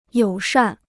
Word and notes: 友善: yǒu shàn

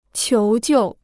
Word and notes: 求救: qiú jiù

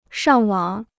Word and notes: 上网: shàng wǎng

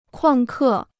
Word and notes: 旷课: kuàng kè

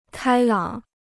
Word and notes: 开朗: kāi lǎng

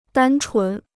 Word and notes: 单纯: dān chún

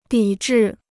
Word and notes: 抵制: dǐ zhì

